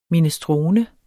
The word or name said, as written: minestrone